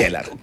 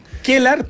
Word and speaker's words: Ckelar: Se escribe C-K-E-L-A-R. – Ya. Kelar. Kelar, pero con una C antes de la K.